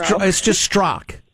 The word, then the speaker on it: Strzok: Oh, it's just Strzok. The people from that part of the world like their silent letters.